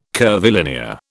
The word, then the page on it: curvilinear: British English